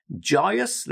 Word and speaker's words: joyous: Okay, so the next word is joyous. Let's just practice the pronunciation. Joyous, joyous. Two syllables, joyous, joyous.